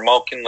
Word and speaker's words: Malkin: Is it Malkin or Malkin? Like, I can never understand how to pronounce his name. Like, how come this can't... – It's Malkin. – Malkin. Okay.